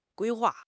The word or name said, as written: 规划